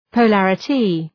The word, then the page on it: polarity: pəʋ’lærətı